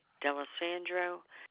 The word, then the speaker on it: D'Alessandro: Ellen, E-L-L-E-N. Middle initial is C as in Carol. The last name is D'Alessandro, D-A-L-E-S-S as in Sam, A-N as in no, D-R-O.